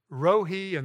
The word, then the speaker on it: Rohi: The next one is Jehovah Rohi, and that's spelled R-O-H-I, and that's the 23rd Psalm.